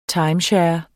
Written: timeshare

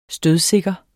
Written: stødsikker

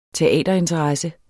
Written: teaterinteresse

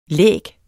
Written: læg

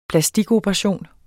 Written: plastikoperation

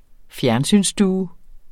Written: fjernsynsstue

